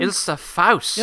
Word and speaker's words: Ilsa Faust: Ilsa Faust.